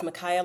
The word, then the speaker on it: Micaiah: I always tell people it's Micaiah like papaya.